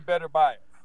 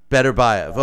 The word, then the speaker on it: Beterbiev: You work with another top fighter, Artur Beterbiev, and how do you pronounce his last name? I've heard it pronounced Beterbiev and Beterbiev. What's the correct pronunciation? – I say Beterbiev. – Beterbiev, okay. – And he's never corrected me.